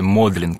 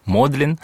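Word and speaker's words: Magdalen: Хотя оно пишется Магдален, то есть, вот, но произносится именно в отношении колледжа Модлин.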